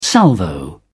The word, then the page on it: salvo: British English